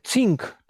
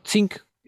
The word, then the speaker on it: cinq: Natomiast już wchodzą potem takie francuskie słowa typu cink, czyli pięć, tak jak się mówi song, pisane C-I-N-Q, to cink, czyta się to po luksembursku.